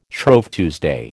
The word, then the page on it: Shrove Tuesday: American English